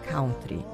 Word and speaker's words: country: Que tal alguém falar corretamente a palavra country? A pronúncia certa é country.